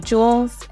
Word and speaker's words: Jules: And you can follow me at the City of Jules, and my, my name is spelled J U L E S.